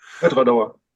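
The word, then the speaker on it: Edradour: Lass uns mal von dem globalen großen Markt wegkommen hin zu dem Signatory Vintage Ettore Douwer. Ettore Douwer, Ettore Douwer, I don't know. Haben wir noch gar nicht drüber gesprochen. Wie wird es eigentlich richtig ausgesprochen? – Etwa Dauer. – Ettore Douwer, sehr gut.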